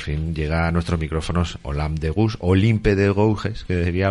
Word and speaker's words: Olympe de Gouges: Muy bien, pues nada, que vamos a hablar de Olympe de Gouges, que hemos dicho que se pronuncia así después de mucho estudio al respecto.